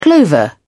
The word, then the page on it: clover: British English